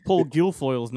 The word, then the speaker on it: Guilfoyle: Nor do I know how to pronounce Paul Paul Guilfoyle's name. Hedaya. Yeah. Dan Hedaya. Dan Hedaya. Paul Guilfoyle. Is that right? Guilfoyle. Guilfoyle. – Yeah.